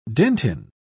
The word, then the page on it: dentin: British English